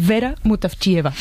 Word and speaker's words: Vera Mutavcheva: Zdravka Eftimova y Vera Mutavcheva.